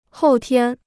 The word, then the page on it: 后天: hòu tiān